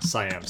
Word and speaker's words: Siam: I left off where she went, because she went to Siam. Yes, it is The King and I. – Is it Siam or Siam? – Siam, sorry.